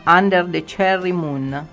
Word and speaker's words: Under the Cherry Moon: Under the Cherry Moon.